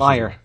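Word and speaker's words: Meier: Is it Meyer or Meyer? Everybody knows I have issues. Meyer. – Meyer, thank you. – Timo Meyer.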